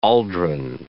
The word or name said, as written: aldrin